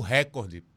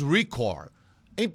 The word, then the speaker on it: recorde: Pelo amor de Deus, a palavra é paroxítona. Não existe recorde. Existe em inglês record. Em português é recorde. É recorde. Aí você vê um cara apresentando um jornal em nível nacional falando recorde. Tá falando errado.